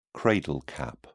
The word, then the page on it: cradle cap: British English